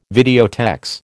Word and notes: videotex: American English

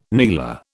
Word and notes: kneeler: British English

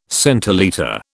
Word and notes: centilitre: British English

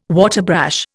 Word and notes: waterbrash: Indian

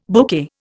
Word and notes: bouquet: Indian

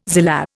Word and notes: zillah: Indian

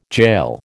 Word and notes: jail: American English

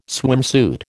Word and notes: swimsuit: American English

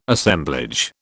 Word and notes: Assemblage: British English

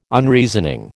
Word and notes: Unreasoning: American English

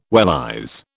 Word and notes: wellies: British English

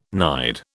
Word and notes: nide: British English

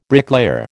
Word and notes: bricklayer: American English

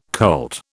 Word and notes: colt: British English

